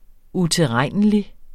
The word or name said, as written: utilregnelig